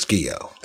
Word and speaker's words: Guillot: And I said, I say, well, a lot of people say that, but we but I pronounce it Guillot. My father asked me to pronounce it Guillot a couple years ago. And so I've been saying Guillot for the last, 45 years. So, yeah, it's Guillot.